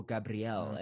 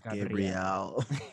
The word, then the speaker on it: Gabriel: He plays the role of Gabriel, or Gabrielle, as they say it. – They pronounce it Gabrielle.